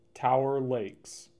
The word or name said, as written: Tower Lakes